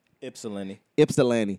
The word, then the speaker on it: Ypsilanti: She in, I never knew how to pronounce this. Is it Ypsilanti? – Ypsilanti.